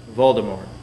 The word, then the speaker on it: Voldemort: And she was like, Voldemort. Isn't that how you're supposed to say it, Voldemort? – You're not supposed to pronounce a T at the end of Voldemort. – Voldemort.